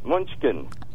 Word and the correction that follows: Mnuchin: Munchkin. – Mnuchin. – Mnuchin, that's it.